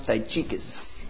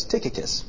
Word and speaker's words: Tychicus: I used to pronounce it Tychicus. And Brandon Claire Simpson laughed so hard, and I didn't know why. And afterwards I asked her, and she's like, well, you might want to ask how that name is pronounced. So I will never forget it. It's Tychicus.